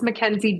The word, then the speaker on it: Mackenzie: You guys can find me on Instagram at It's Mackenzie G. It's Mackenzie spelled with M-A-C-K.